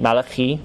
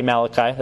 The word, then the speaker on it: Malachi: It says Malachi. That's how you pronounce it in Hebrew. That's his name, Malachi. We say Malachi. That's fine. Malachi.